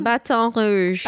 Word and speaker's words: Baton Rouge: Baton Rouge.